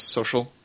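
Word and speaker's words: So.cl: You know that that's pronounced social? – I know, but it's written Sokal.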